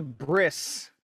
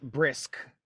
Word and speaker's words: bris: But it's a bris, not brisk. – So no K?